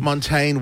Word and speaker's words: Montaigne: I was in the blue Montenegro... How do I say it? – Montane wig.